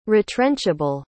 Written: retrenchable

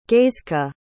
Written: gaizka

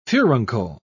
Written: furuncle

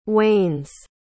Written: wanes

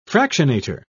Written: fractionator

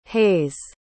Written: hays

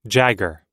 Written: jagger

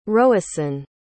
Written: róisín

